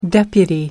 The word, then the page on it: deputy: American English